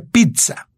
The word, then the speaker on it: pizza: Y en realidad, en italiano se pronuncia pizza, o sea, con, con sonido T.